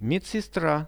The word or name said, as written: medsestra